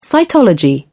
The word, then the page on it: cytology: British English